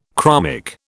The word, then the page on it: chromic: British English